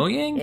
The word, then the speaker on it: Mojang: Mojang. Is mm-hmm. it Mojang? – It is.